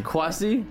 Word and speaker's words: Kwasi Mensah: And we have comedian Quasi Menza. Did I say that right? – That was a pretty good attempt. Oh. Hey. Pretty good attempt. Think like Quasi, like Jesse. – Quasi? – Yeah.